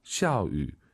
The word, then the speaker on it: 下雨: 비 오다 발음 다시 들어보고 싶어요. – 샤유. – 샤유. 샤유 꼭 기억하시고요.